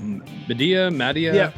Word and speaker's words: Madea: And he was wearing a gold dress and a red wig, and he straight up looked like a Madea. Is that how you say it? Madea? – Yeah, no, you got it.